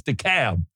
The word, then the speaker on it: DeKalb: That's how they say it around here, DeKalb. – No, they don't. It's DeKalb. – I thought it was DeKalb.